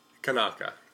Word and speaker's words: Kanaka: And it's powered by Kanaka or Kanaka? – Yes. Kanaka. – Kanaka. – Hey, listen, as long as you pronounce it.